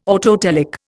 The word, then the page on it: autotelic: Indian